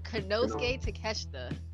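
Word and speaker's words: Konosuke Takeshita: Kanoki Kaneshita. Am I saying his name right? No, I'm not. – Kanosuke Takeshita. I had to practice that.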